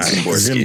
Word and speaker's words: Szymborski: the S and the Z are together in that, I think that means uh, it just pronounced like a Z. So it's like Zimborski.